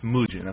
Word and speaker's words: Mugen: It's Mugen, okay. – Mugen or Mugen, Mugen.